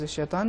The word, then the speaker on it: شیطان: لفظ شیطان میں یہ لفظ طا، حرف طا یہ تفخیم کے ساتھ ادا ہوگا، لیکن جو دو حرف ہیں